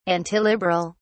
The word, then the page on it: antiliberal: British English